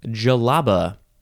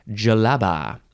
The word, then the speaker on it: djellaba: It is pronounced jalaba. D-J-E-L-L-A-B-A, or you can add an H at the end. Jalaba.